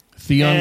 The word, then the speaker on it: Theon: Theon. – Theon.